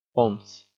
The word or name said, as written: ponce